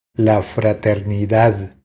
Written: La fraternidad

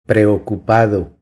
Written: Preocupado

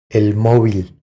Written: El movil

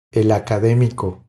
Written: El academico

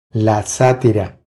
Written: La satira

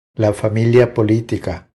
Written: La familia politica

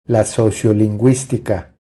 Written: La sociolinguistica

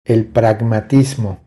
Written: El pragmatismo

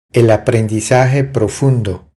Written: El aprendizaje profundo

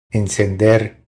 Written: Encender

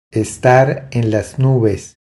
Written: Estar en las nubes

